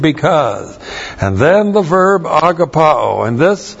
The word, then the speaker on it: agapao: Agapao. A-G-A-P-A long O. Agapao. That's the verb.